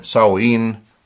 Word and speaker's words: Samhain: Halloween beruht auf dem keltischen Fest, und dann sagen die, das soll ausgesprochen werden, Samhain, S-A-M-H-A-I-N, geschrieben, einige haben da andere Aussprachen. Es ist ein vorchristliches Fest, es war eins der vier heiligsten Tage im keltischen Kalender.